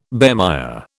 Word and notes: bemire: British English

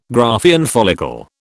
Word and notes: Graafian follicle: British English

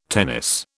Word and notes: tennis: British English

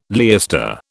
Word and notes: leister: British English

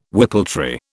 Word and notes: whippletree: British English

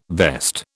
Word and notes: vest: British English

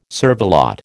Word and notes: cervelat: American English

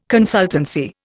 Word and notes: consultancy: Indian

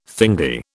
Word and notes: thingy: British English

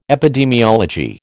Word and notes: epidemiology: American English

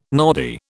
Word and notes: noddy: British English

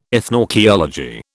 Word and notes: ethnoarchaeology: British English

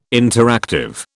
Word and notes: interactive: British English

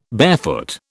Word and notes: barefoot: British English

